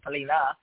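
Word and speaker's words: pallino: You roll a ball to a palina, a white ball. That's the pronunciation, palina.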